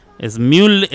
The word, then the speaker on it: Smule: Smule, Smule se escribe Smule, Smule, empieza con S.